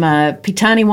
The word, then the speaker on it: Petane: So, Philip Barber from Petane Wines, or is that how you pronounce it? – Exactly. Fantastic.